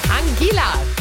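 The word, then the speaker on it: Anguilla: Angela! Habe ich richtig gesagt, Simon? – Ja, würde ich sagen, weil es tatsächlich zu Großbritannien mit dazu gehört.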